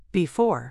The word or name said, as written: before